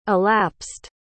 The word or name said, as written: elapsed